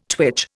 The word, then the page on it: twitch: Indian